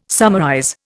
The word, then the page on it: summarize: Indian